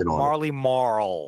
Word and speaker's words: Marley Marl: That, yeah, real, but it's the hard L, Marley Marl.